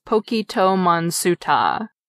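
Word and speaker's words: Pokito Monsuta (ポケットモンスター): The name of this popular kids' game is a shortened version of Pocket Monsters, pronounced in katakana as Pokito Monsuta.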